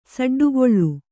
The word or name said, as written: ಸಡ್ಡುಗೊಳ್ಳು